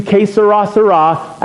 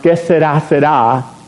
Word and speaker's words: que sera, sera: Or are we just allowing whatever happens in life, and it's going to be que sera, sera. Actually, it should be pronounced que sera, sera. But I'll give you the Americanized version.